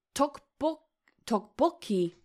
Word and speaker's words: tteokbokki: Tteokbokki.